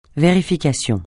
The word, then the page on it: vérification: francais